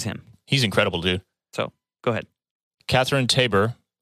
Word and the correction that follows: Taber: apologies if I butchered that, as Padme Amidala. Yeah, dude, it's Taber. Taber. Taber.